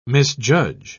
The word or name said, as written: misjudge